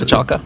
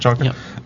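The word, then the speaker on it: Kochalka: I wanted to ask you about the book you did with James Kolchaka. Is that how you pronounce Kolchaka. – It? Kolchaka. – Yep.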